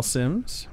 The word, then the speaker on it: Sims: Sims. – And Sims is spelled S-Y-M-M-E-S. – S-I-M-S.